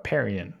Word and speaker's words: Perrien: Perrien.